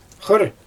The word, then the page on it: xɯr: Xyzyl